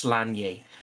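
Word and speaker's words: Sláine: But Slanye, I believe, is how you would pronounce it. The same way as N-A-I-A-M-H is Neve. So I think it's pronounced Slanye.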